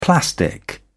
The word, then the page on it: plastic: British English